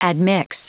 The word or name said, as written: admix